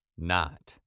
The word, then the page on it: not: British English